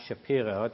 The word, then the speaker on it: Shapiro: My name is Noad Shapiro. That's spelled S-H-A-P-I, R as in Robert, O.